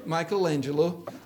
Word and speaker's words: Michelangelo: How do we say it properly? Michelangelo. Michelangelo.